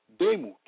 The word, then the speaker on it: demut: The word likeness in Hebrew is domut, pronounced demut, meaning model, shape, fashion, the way that we are made, manner, similitude, having similarity or double.